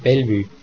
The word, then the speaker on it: Bellevue: Bei Wörtern aus dem Französischen wie Fondue oder Bellevue ist die Aussprache wie im Französischen, allerdings mit Schweizer Akzent, das heißt sogar mit Betonung auf der ersten Silbe, also Fondue und Bellevue.